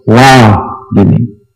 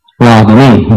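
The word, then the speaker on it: Radini: But sometimes the accent falls on the first syllable, as it does in this case. Ra-dini. Ra-dini.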